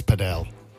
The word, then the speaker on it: padel: Well, if you like squash a little bit and you also like tennis, then paddle. – Yeah, but sorry, can I just stop you? It's padel.